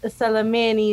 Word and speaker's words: Salamin: Salamin, you spell it as S-A-L-A-M-I-N. – Mm-hmm. – So the word that's very close to salamin is salami.